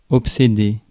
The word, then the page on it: obséder: France; [ɔp.se.de]